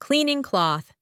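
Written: cleaning cloth

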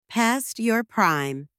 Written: past your prime

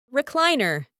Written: recliner

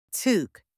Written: tuque

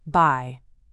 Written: by

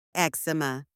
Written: eczema

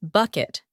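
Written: bucket

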